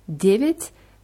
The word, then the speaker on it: девять: Девять wird mit je ausgesprochen.